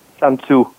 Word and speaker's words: Sun Tzu: Sun Tzu.